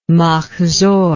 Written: machzor

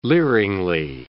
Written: leeringly

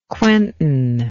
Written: Quentin

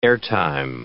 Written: airtime

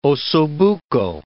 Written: osso buco

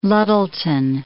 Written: Luttelton

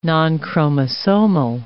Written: nonchromosomal

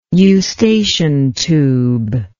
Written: eustachian tube

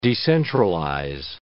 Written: decentralize

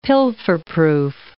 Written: pilferproof

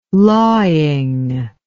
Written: lawing

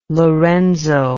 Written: Lorenzo

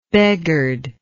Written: beghard

